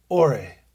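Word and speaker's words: ore (אור): The Hebrew word here is pronounced ore.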